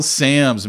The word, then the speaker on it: Sams: Did I say your last name right? Because I always see it, and the two M's throw me off. – Yeah. So long backstory on that. But yeah, Sams, you said it right.